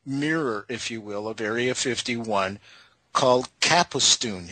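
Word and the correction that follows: Kapustin Yar: Have I got that right, Bill? – It's Capustin Yar. – Capustin Yar. Okay. Much better pronunciation than what I just destroyed.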